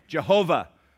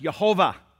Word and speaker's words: Yehovah: And in this passage, the word Lord, L-O-R-D, capital L, capital O, capital R, capital D, in all caps, is the name Jehovah, or as it is properly pronounced, Yehovah.